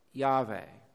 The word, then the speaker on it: Yahweh: This is not a title, but the Hebrew name for God, perhaps should be pronounced Yahweh.